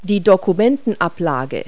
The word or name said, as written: desk tray